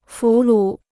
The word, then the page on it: 俘虏: fú lǔ